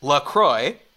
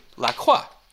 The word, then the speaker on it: LaCroix: The company insists it's pronounced LaCroix, but really, it should be LaCroix.